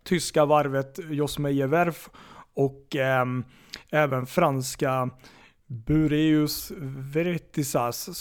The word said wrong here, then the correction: Bureau Veritas: som... Bureau Veritas ja. Klassningssällskapet här. – Precis, så kanske man uttalar det.